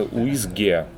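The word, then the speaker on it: uisge: А на, на шотландском, гельском это уизге, то есть чуть-чуть отличается произношение, но да.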